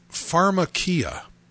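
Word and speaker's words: pharmakia (φαρμακεία): But the Greek word for sorcery and witchcraft is a word that is pronounced pharmakia.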